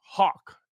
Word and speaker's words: Hauk: Now, a white pill moment is the last trial we have to cover, which I think you pronounce the name Hawk, H-A-U-K.